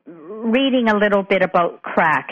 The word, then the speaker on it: craic: And the word that you're referring to in Irish is pronounced crack. – Crack, okay.